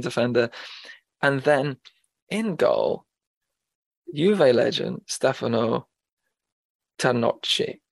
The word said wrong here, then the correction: Tacconi: Tacconi. Forgive my pronunciation.